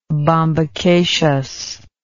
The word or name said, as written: bombacaceous